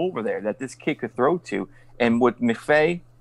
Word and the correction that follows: McVay: right? McVay. Am I am I butchering that?